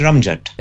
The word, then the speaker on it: Ramzat: It's pronounced Ramjet.